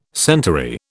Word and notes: centaury: British English

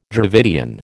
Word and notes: Dravidian: American English

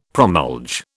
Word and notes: promulge: British English

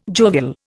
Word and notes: jovial: Indian

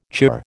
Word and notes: chirr: American English

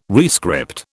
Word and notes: rescript: British English

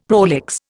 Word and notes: prolix: Indian

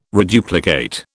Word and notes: reduplicate: British English

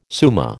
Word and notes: summa: American English

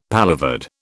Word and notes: paloverde: British English

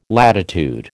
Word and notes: latitude: American English

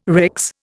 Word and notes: RICS: Indian

